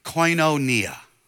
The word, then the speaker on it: koinonia: It is pronounced koinonia.